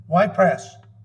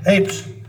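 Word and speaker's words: Ypres: Y-P-R-E-S. Y Press. E Press. Apes. Apes. I spelled with a Y, but it's apes.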